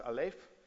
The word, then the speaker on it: Aleph: This symbol up there is pronounced Aleph, and it's a picture of two oxen yoked together.